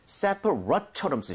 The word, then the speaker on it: separate: separate처럼 쓰셔야 돼요. – 아. – So, rate이 아니라 rut. – rut.